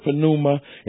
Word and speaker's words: pneuma: In the Greek, you pronounce the P. You know, it's not silent. They don't use any silent letters. Every one is pronounced. We would just say pneumatic, and we drop the P in English, you see.